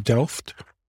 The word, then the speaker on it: Delft: Well, now IKEA's headquarters is it pronounced Delft? – Yes.